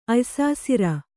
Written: ಅಯ್ಸಾಸಿರ

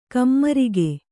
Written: ಕಾಮರಾಗ

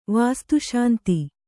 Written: ವಾಸ್ತುಶಾಂತಿ

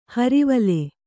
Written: ಹುರುವಲ್ಲಿ

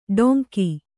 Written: ಢೋಂಗಿ

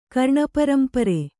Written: ಕರ್ಣಪರಂಪರೆ

